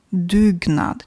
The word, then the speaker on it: dugnad: Se scrie cu U românesc, dar se pronunță ca în Scandinavia U, dugnad.